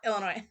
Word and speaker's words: Illinois: I'm like, Illinois! And I'm like, oh, Illinois. – So, technically, it is spelled with an S. – The S is silent, just like far.